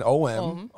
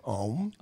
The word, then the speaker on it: Om: So as in O-M. – Om. – Om. Pronounce it right. Om.